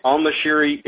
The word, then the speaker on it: al-Nashiri: In November, the Attorney General himself says al-Nashiri is actually the correct pronunciation.